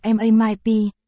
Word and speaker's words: MMP: Phát âm là MAMIP, có thể viết thành từ viết tắt MMP.